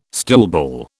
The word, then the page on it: stoolball: British English